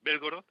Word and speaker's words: Belgorod: En este caso en Belgorod, algunos lo pronuncian Belgorod, otros Belgorod, yo creo que es Belgorod, en esdrújula.